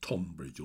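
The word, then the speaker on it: Tonbridge: It's spelt with an O, so Tunbridge or Tunbridge.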